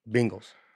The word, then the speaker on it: Bengals: Bengals, not Bengals, are Bengals. Y'all are killing me. Short E, guys. Short E. Bengals.